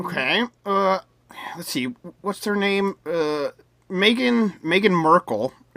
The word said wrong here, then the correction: Markle: Is that her name? Markle? – Yes. – I always forget Markle. How to pronounce her last name.